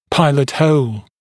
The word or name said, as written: pilot hole